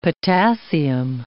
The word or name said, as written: potassium